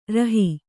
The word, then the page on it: ರುಹೆ: Kannada